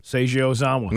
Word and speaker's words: Seiji Ozawa: Seiji Ozawa. – Seiji Ozawa.